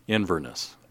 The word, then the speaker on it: Inverness: So it's in Inverness. Am I saying that right? – Inverness.